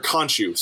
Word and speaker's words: Khonshu: He essentially gets possessed by Kushu, the moon god, the Egyptian moon god, and Kushu. Con, or Khonshu, Sorry, Khonshu. I said that wrong. It's not C. It's K O N C H U.